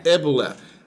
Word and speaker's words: Ebule: We have Lenora Uble. Am I saying that right? – Ebule. – Ebule. Lenore Ebule of Bailan Spice.